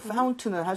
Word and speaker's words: Fountain: 그래서 파운틴 하는 사람도 있지만은 파운 해서 T 사운드가 거의 날아가는 현상이 벌어집니다. 보통 이게 튼으로 생각되는 발음들이 많이 그래요. – 네. – 그래서 자, 한번 try 해보도록 할게요. Fountain은 할수 있으니까 Fountain, Fountain.